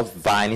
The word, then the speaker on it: Vyne: Vine Henshaw. Vine is spelled VYNE_Henshaw.